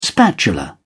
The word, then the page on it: spatula: British English